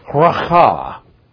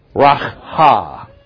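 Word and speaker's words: ra'ah: It is spelled R-A-apostrophe-A-H. And it is pronounced in Hebrew, racha. Say it with me. Racha.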